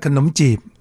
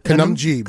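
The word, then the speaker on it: khanom chip: Well, we call it kanom chip. – Okay. Kanum jeep. – Kanum jeep, jeep.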